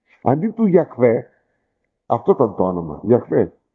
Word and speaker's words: Γιαχβέ: Λοιπόν, οπότε, κατά προσέγγιση λέμε ότι πρέπει να προφέρεται το όνομα Γιαχβέ ή Ιεχωβά. Φαίνεται ότι καλύτερο πιο προσεγμένο είναι και πιο κοντινό είναι το Γιαχβέ και όχι το Ιεχωβά.